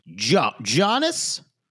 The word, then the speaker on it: Giannis: Giannis? There's a G there. From what I know in America, we say Giannis.